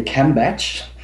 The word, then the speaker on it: Kambach: Oh, this is really a funny story, because there are many ways in pronouncing this name. So most of the people, they say Kambach, Cambodge.